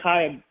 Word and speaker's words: Chaya: So when Chaya Hanan, Right, that's how she pronounces her name, C-H-A-Y-A, space C-H-A-N-I-N, Chaya Fanin.